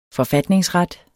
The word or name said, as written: forfatningsret